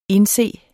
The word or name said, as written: indse